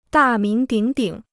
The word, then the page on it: 大名鼎鼎: dà míng dǐng dǐng